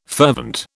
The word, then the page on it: fervent: British English